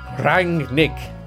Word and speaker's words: Rangnick: And we'll also, I think, finally be getting a definitive answer on how to pronounce the new Manchester United interim manager's name. – Rangnick.